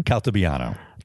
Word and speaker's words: Caltabiano: How do you pronounce his last name? – Caltabiano.